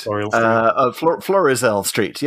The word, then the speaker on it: Florizel Street: But there are so many other references going on here, like the, what was it called? Faisal Street. – I can't pronounce it. – Florial Street. Florizel Street. Yeah. We had half of it each.